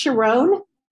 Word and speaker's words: Sharon: It's pronounced Sharon, like the song, My, My, My, My, My, Sharona without the A.